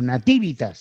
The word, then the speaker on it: nativitas: También conservaron la palabra navivitas, nativitas, perdón, o nativitas sería la pronunciación correcta, que en latín designaba el aniversario del nacimiento de un emperador o del día de su ascensión al trono.